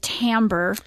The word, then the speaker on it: timbre: There's a fun word called timbre. It's spelled T-I-M-B-R-E. Timbre.